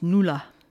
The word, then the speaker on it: Nuala: She followed on from Nuala Kennedy, spelled N-U-A-L-A, Nuala, pronounced Nula.